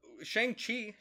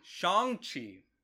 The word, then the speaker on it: Shang-Chi: Shang-Chi. Shang-Chi.